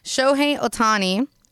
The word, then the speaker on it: Shohei Ohtani: Shohei Ohtani, and I hope I said his name right, because...